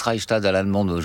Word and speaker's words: Reichstadt: Je prononce Reichstadt à l'allemande, je peux ? – Oui, vous pouvez.